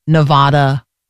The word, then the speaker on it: Nevada: Okay, so Idaho, Missouri, Nevada, and Virginia. I have to always remember, if I had a gone to Nevada, that's how I remember how to say it correctly.